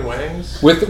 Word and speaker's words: Wyngs: Who wants to buy chicken wangs? – So you can't see this at home, but it was spelled with W-Y-N-G-S. Chicken Wangs.